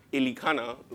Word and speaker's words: Elkanah: there was a man named Elkanah, Elikana, like um, Uncle B pronounced it, Elikana.